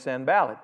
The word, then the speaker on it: Sanballat: 19 says, but when Sanballat, and I may not be saying that right, but does anybody know how to say it? Then it's Sanballat.